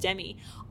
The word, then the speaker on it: Demi: You know, the funny thing about the name, Demi Moore, I think she pronounces it Demi, but, you know, we all know her as Demi.